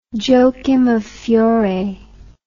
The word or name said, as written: joachim of fiore